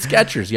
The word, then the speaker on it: Skechers: M-A-Y-E-R, like John Mayer. – Okay. – Skechers. Mm-hmm. Shoes. There's no T in Skechers. You're like, whoa, what? No T in Skechers, y'all. No T. – So Skecher. – Yeah. – Sketch. – I never thought about it that hard. – It's no T. – How would you pronounce it then? – Skechers. That's how you pronounce it. Just no T. – Okay. – Fruit Loops. – Oh, boy. – F-R-O-O-T.